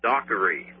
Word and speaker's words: Dockery: It's A-R-G as in Gene, Genie, E-N-Z-I-A-N-O. That's A-R-G-E-N-Z-I-A-N-O. And the last one is Dockery, like Hickory, Dickory, and Dockery. Okay, D-O-C-K-E-R-Y.